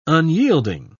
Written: unyielding

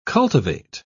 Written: cultivate

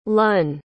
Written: lunn

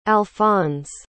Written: alphonse

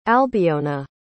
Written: albiona

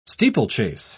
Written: steeplechase

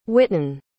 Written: whitten

